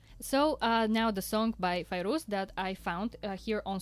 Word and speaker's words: Fairuz: And I read a lot about the singer Fairuz.